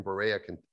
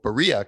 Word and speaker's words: Berea: But now she's in Berea, no, Berea, Kentucky, excuse me. I learned that it's pronounced differently.